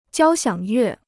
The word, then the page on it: 交响乐: jiāo xiǎng yuè